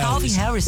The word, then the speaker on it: Calvin Harris: Adorei essa música do Calvo Harris. – Eu falei errado. Eu falei Calvo Harris? – Falou, não falou, Yas? Eu falei Calvo. – Falou Calvo Harris. – É Calvin Harris.